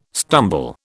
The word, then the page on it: stumble: British English